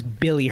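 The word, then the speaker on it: Willy: So this is his mother is German, but he's born in Spain, and apparently the W is pronounced as a B. – Oh, really? – By the German people. So he is Billy Hernan Gomez.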